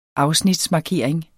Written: afsnitsmarkering

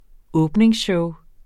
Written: åbningsshow

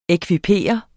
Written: ekvipere

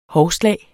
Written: hovslag

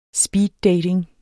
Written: speeddating

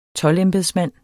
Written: toldembedsmand

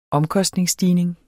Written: omkostningsstigning

